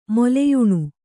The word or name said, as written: ಮೊಲೆಯುಣ್ಣು